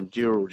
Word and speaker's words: Dural: So Dural. Yeah, yeah. That's what Dural, as in D-U-R-A-L. And that's what apparently in the Indigenous language, it's Dural, as in D-O-O-R-A-L.